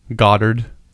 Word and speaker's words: Godard: Goddard, as Americans say it.